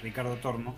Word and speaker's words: Ricardo Tormo: este, en el autónomo Ricardo Tormo de Valencia, hoy lo dije bien, Ricardo Tormo,